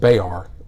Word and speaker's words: Bexar: which is Bexar, which is pronounced Bayar down there,